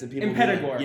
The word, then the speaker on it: Impetigore: Impetigore.